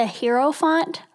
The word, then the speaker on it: Hierophant: So I think, like, the way it can really be solidified in my own head, I think I'm going to just call it the Hierophant from now on. That seems to roll off my tongue a little bit better.